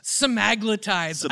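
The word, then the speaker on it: semaglutide: Semaglutides.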